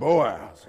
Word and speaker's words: Boaz: Remember, guys, you got to say his name like Boaz.